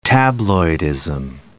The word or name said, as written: tabloidism